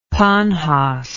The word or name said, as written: panhoss